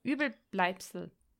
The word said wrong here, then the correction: Überbleibsel: so gut, ich hoffe, ich habe es richtig ausgesprochen. – Überbleibsel, ja, ist korrekt.